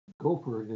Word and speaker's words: gaufre: Pommes frites, gaufre, or chocolat? – I like the gaufre. Remember the gaufre, the waffles? – Yep. – Gopher is the French pronunciation of waffle.